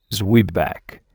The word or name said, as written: zwieback